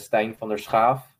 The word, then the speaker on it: Stein van der Schaaf: The Dutch way to pronounce it is Stein van der Schaaf. So of course, with the ch, which is a very common sound used in the Dutch language.